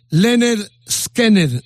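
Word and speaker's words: Lynyrd Skynyrd: Pronúnciese Leonard Skinner.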